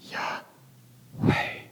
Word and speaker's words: Yahweh: But scholars and rabbis have noted that the letters YHWH represent breathing sounds or aspirated consonants. When pronounced without intervening vowels, it actually sounds like breathing. – Yahweh. Yahweh.